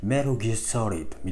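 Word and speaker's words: Metal Gear Solid: メタルって発音しないですよメタルギアソリッドをなんか英語のネイティブな発音で聞くとメルギアソリッドみたいな感じなんですよあれ T どこ行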